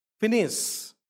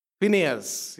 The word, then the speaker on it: Phinehas: Phinehas, or how do you pronounce it? Phinehas, yes. But the Hebrew pronunciation is Phinehas. That's how it is typically done.